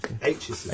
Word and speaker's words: Aitchison: Um, second album from uh, Charlotte Atchison. Atchison. – Aitchison. Aitchison, yeah.